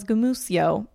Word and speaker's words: Gamuccio: Jo, det är Greg Gamuccio som tydligen uttalas gumusio, men svårt för min hjärna att ta in den informationen.